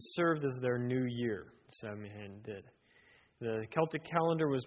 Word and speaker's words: Samhain: So when we talk about the history of the holiday, we need to start with Samhain, or as it's really actually correctly pronounced, Samhain. But I'll pronounce it Samhain. It is spelled Samhain, and that's a Celtic word.